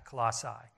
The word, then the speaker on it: Colossae: Well, this church, it's pronounced different ways. I'm not very good with pronouncing words anyways. It's Colossae. Everybody calls it Colossae. It's actually Colossae.